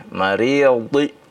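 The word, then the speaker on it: al-maridhi: Jadi wa 'alal wala 'alal maridi di, bukan di, bukan ya, dari huruf dhad itu keluarnya dari ya sisi lidah ya yang bagian belakang bertemu dengan gigi geraham ya. Mariyadhi ya. Nah, begitu bacanya.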